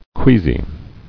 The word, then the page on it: Queasily: quea·sy